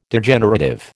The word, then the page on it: Degenerative: American English